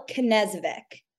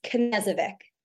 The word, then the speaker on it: Knezovic: Michelle Knezovic. So a lot of people think you don't pronounce the K, Knezovic. People think there's an H on the end, but it's just Knezovic.